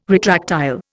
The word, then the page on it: retractile: Indian